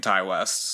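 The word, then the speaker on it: Ti West: I watched a couple interviews just to make sure we were getting the pronunciation of the name correct, and everyone has been saying Ty West, so okay.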